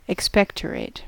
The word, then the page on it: expectorate: American English